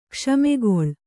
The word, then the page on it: ಕ್ಷಮೆಗೊಳ್: kṣamegoḷ